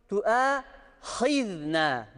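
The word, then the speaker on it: Tu'a hizna (تُؤَاخِذْنَا): Şimdi la tu'ahizne deniyor buraya. Ayın gibi okunuyor. Bakın burası ayın harfi değildir. Burası eliftir. Tu'a. Burası hizna deniyor. Bakın ya h gibi okunuyor ya da ha gibi okunuyor. Tu'a hizna diye okumamız lazım. Ha değildir, h değildir.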